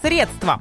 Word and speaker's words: средство: Итак, сегодня слово «средство». Будь то жидкость для уборки дома или какие-то денежки, ударение всегда ставится на первый слог. Средство, независимо от смысла.